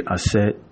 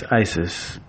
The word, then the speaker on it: Aset: The deity to call on in this time would be Aset. The Greeks mispronounced her name as Isis, but she is the goddess of the moon.